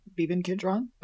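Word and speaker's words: Beeban Kidron: So the director of this movie, I have not heard of this person before, but apparently she also directed a Bridget Jones, Beben Kidron. Am I saying that right? – I think so. Yeah. Beben Kidron. I think that's right.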